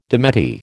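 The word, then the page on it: démenti: American English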